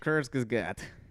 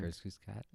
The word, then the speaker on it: Kurzgesagt: Kurzgesagt. That's how you say it, I think. – Kurzgesagt.